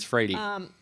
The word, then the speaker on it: Freire: I said it wrong the whole time. Okay. I asked a Brazilian lady recently. She told me it's Freire.